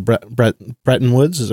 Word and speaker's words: Bretton Woods: And just as a side, the most recent episode of Planet Money, which is a repeat, actually covers that original Bretton Woods, am I saying that right? – Yeah.